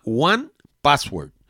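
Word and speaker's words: 1Password: Se escribe L-A-S-T-P-A-S-S. Y el que yo utilizo se llama One Password. Y es el número uno y la palabra password. No es one con O-N-E. Es uno password.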